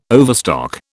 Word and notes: overstock: British English

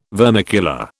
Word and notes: vermicular: British English

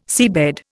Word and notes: seabed: Indian

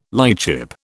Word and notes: lightship: British English

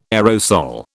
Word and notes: aerosol: British English

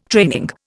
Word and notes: training: Indian

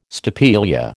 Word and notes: stapelia: American English

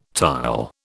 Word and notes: tile: British English

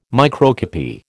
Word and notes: microcopy: American English

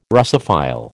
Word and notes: Russophile: American English

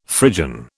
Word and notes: Phrygian: British English

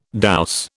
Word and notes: douce: British English

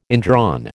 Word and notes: indrawn: American English

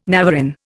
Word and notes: navarin: Indian

